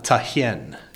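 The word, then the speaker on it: Tạ Hiện: Ta Hien Street, I hope I'm pronouncing that correctly. – Ta Hien? – Ta Hien. T-A-H-I-E-N, mm-hmm.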